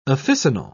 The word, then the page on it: officinal: British English